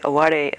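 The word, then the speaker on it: aware (あわれ): In Japanese, the word aware. It's spelled like aware, A-W-A-R-E, but it's aware.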